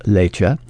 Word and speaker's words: Leite: again spelled L-E-I-T-E, pronounced Leitche,